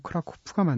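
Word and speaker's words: Kraków: 일반적으로는 크라코우라고도 더 말을 많이 하는데 영어식으로 사실 현지 발음대로 하면 크라코프가 맞는 이름이고요.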